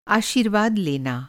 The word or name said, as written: आशीर्वाद